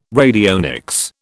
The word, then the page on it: radionics: British English